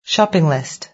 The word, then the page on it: shopping list: British English